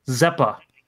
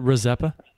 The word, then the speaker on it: Rezeppa: Zeppa. – Isn't it Rezeppa? – Yeah, the R is not silent. I've always heard the R pronounced.